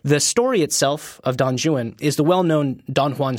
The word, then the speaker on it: Don Juan: Tell us what Don Juan is about, and why is it called Don Juan instead of Don Juan, as I've been pronouncing it my entire life.